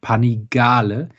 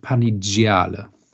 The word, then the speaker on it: Panigale: Und zwar, wenn im Italienischen nach C und G ein I oder ein E kommt, dann wird es zu G und wenn es ein oder zu J und wenn danach ein A, ein O oder ein U kommt, dann ähm, wird es so wie der Buchstabe an sich ausgesprochen. Das heißt Panigale und nicht Panigiale.